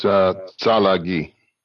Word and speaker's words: Tsalagi: The Cherokee people have another name for themselves, which, how do I properly pronounce that name for the Cherokee? – It's Tsalagi.